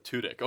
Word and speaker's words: Tudyk: This is another hard pronunciation. Alan Tudyk? – Tudyk, I think, is it. – Tudyk. Oh, you checked it out.